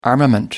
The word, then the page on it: armament: American English